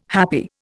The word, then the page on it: happy: Indian